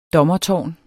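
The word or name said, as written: DOMMERTÅRN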